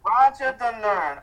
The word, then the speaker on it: Rajadamnern: Raja Damnern, RAJADAMNERN.